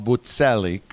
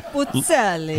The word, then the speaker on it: Buzzelli: Bob's real name is pronounced Bob Buzzelli because the double Z in Italian. So everyone from Bloomfield's been calling him the wrong name all these years. – Buzzelli.